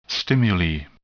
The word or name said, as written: stimuli